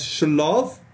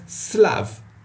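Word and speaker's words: slav (שלו): It's written with a shin, so you might read it as shlov, but we know to pronounce it as slav.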